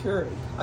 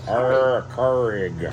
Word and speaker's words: Keurig: So originally, we were big into the Keurig. So we tried the little self-filled K-Cups. Remember? – Oh, the Keurig. You said the curry. I'm like, what? – Oh, my goodness, love. Keurig.